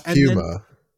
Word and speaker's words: puma: Greg says this wonderful line, if you use Marmite, I will be on you like a puma, my friend. – Well, he says Puma. – Puma, I'm sorry.